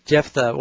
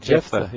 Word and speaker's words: Jephthah: Yeah, Jephthah, well, at least in English. – Okay. – How do they say it in South Africa, Pastor Ross? – Same thing, Jephthah, yeah.